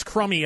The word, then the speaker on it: Crumby: It's Crummy as in crumbs that fall off of things that you have to clean up.